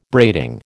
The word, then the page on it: braiding: American English